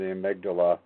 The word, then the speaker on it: amygdala: It comes from the part of the brain called the, I'm going to spell it, amygdala.